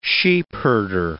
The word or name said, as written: sheepherder